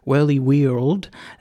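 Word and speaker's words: Whirlywirld: It's hard to pronounce because it's got an I instead of O, where world is spelt. So it's Whirly World.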